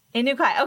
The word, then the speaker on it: Inukai: Inukai.